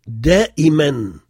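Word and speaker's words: daimen (دائماً): C'est un alif qui porte deux traits et on prononce N. Donc, le premier, daimen.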